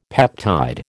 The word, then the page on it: peptide: American English